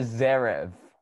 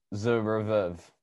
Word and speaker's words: Zverev: Alexander Zverev from...